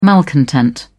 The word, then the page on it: malcontent: British English